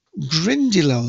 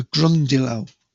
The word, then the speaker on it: Grindylow: Grindylow, let me spell it, G-R-I-N-D-Y-L-O-W, Grindylow. It's sometimes called a Grundylow.